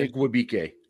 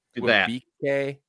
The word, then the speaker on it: Igwebike: Say the returner's last name. – Godwin Igwebike. – Igwebike? Yeah. – Igwebike.